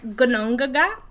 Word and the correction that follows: Ginnungagap: Yeah, okay. Gnungagap is Ginnungagap.